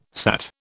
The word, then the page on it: sat: British English